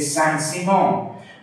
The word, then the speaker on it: Saint-Simon: And they identify this with the school associated with Saint-Simon, which in English would be pronounced Saint Simon, with a hyphen. The Saint-Simonians, whose greatest exponent was Auguste Comte, C-O-M-T-E,